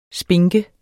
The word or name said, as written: spinke